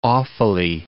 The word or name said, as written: Offaly